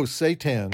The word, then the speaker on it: seitan: Not Satan, but you know, that word I can't pronounce very well. Satan. – Oh, seitan, right.